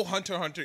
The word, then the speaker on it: Hunter Hunter: I feel like Hunter X Hunter. I know Hunter Hunter is the correct way to say it.